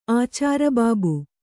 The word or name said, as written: ಆಚಾರಬಾಬು